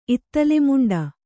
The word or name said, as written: ಇತ್ತಲೆಮುಂಡ